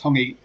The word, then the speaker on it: hongi: So, um, uh, hangi, uh, or the uh, uh well, sorry, whatever. I, I, sorry, I got pronunciation wrong. But the Maoris like to touch nose to nose. Um, as yeah, as the as. hongi. – So the hongi. The hongi.